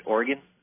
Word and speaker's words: Oregon: Oregon? – Yes, thank you.